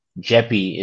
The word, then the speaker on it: JEPI: Uh, the, these involve covered calls as well as owning individual stocks and an ETF like JEPI, JEPI is how I would pronounce it, which is a JP Morgan equity diversified income ETF.